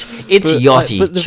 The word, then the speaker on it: Yachty: How do you pronounce Yachty? – It's Yachty. It is Yachty.